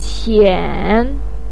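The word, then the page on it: qian3: tone 3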